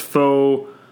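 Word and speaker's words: Fårö: All right, so I just finished watching, I kept on saying Pharaoh document, but I believe it's pronounced Foria. Fora? I'm going to say it wrong. Let's say, I know it's Foreuh is the pronunciation, so Fora. Let's say Fora.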